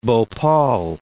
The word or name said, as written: Bhopal